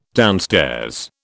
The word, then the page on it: downstairs: British English